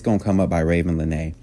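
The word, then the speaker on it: Ravyn Lenae: Her name is Raven Lene. Now, you don't spell it like Raven Simone. It's R-A-V-Y-N Lene, L-E-N-A-E. Raven Lene.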